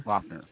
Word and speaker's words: Loughner: Is that how you say his name? – Loughner. Yeah.